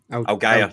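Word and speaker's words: Allgeier: Is it Aligier, or I always forget how you pronounce his name? – Algier, that's it. – Sorry, Algier.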